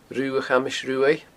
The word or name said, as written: Rubha Chamais Ruaidh